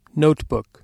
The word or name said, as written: notebook